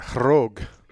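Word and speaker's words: Khorog: I think they pronounce it Kharog. Yeah. It was almost like the K is silent, Kharog.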